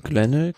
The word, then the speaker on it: Glenelg: Ähm, nächster Tag war dann die fünfte Etappe von Glengel, Glenelg, wie spricht man das? Glenelg? Glenelg? Glenelg, ja.